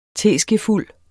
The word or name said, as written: TESKEFULD